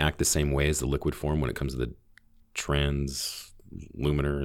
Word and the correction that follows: translaminar: Oh, the translaminar activity? – Laminar, yeah.